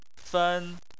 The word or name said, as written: fen1